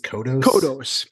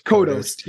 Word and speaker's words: Kodos: I love the cameo we get again from our two alien friends, Kang and Kudos. Do I say that right? I always say Kudos, but I think it's Kodos. – Kodos. Yeah.